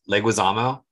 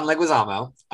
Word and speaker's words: Leguizamo: Voiced by John, oh God, I'm going to butcher this, Leguizamo? – You did not butcher it. You got it right. – Oh, yeah. Okay, sorry. John Leguizamo, which for gay reasons, I'm only going to mention this one film that he was in, which is called Tu Wong Fu.